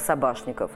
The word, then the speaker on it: Собашников: Он должен был быть Собачниковым, но он пишется и произносится как Собашников.